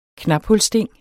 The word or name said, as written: knaphulssting